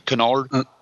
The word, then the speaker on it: Kennard: Oh, and Adina's Noah Kennard. I hope I'm saying his name right, John. Do you know Kennard? – That sounds right.